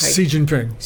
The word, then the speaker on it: Xi Jinping: I never say his name as clearly easily as you say, Xi Jinping. – Right? Xi Jinping.